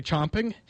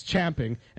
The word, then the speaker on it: champing: And now, every time I hear somebody say chomping, I immediately say in my head, or sometimes out loud, it's champing.